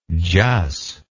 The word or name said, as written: jus